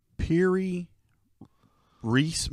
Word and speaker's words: Piri Reis: Piri Reis Map. I don't know if I'm pronouncing that right. What? Correct. It's spelled P-I-R-I-R-E-I-S.